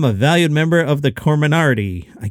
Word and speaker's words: Comirnaty: That name is Comirnaty. Comirnaty. I'll spell it for you. C-O-M-I-R-N-A-T-Y.